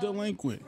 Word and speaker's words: Dlequint: It's pronounced delinquent. – It's pronounced delinquent, bitch.